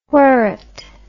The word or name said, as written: wherret